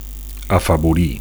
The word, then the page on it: afavorir: Catalan